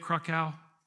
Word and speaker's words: Krakauer: And if you've read John Krakauer's book, Krakauer, is that how you say it? Krakauer? Yeah.